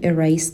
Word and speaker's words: erase: Kirsty hasn't just figured out how to erase the pain of being wrong. به تلفظ کلمه erase دقت کنین، صدای سه داره. Kirsty hasn't just figured out how to erase the pain of being wrong.